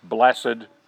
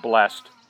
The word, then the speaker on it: blessed: Blessed is one of them. You always like to pronounce the ED. Okay? It's not blessed. It's blessed. It's like the Lord's Prayer.